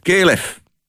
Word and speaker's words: KLF: Ik zeg KLF, maar ja, goed, het is, natuurlijk, het is natuurlijk Engels. En dan moet je het ook in het Engels uitspreken. Heel goed, KLF.